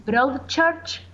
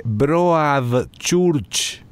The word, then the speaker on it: Broadchurch: Perdóname, se escribe Broadchurch. – Exactamente. – Ah, okay. – La iglesia de proa. – Ajá, sí, okay. – Algo así. Sí. – Y arranca como Broadway.